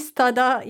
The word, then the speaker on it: Stoddart: So the T is silent, is it? – Yeah, Katie Stoddart. Yeah, you can say it or you can skip it. It works both ways.